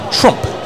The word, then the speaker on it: Tromp: By the way, his spelling of his name, folks, T-R-O-M-P. – Trump? – Trump, yeah. I guess so. – Well, people are saying that that's how it's spelled and that's how it's pronounced.